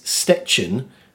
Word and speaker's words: Szczecin: For example, Poland's seventh largest city is Szczecin, which is spelt S-Z-C-Z-E-C-I-N.